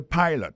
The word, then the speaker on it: pilot: Il écrit P-I-L-O-T. Alors, il s'est prononcé I dans le cas. Alors, et puis, dans le mot pilot là, tout, après accent tombé sur le premier syllabe là.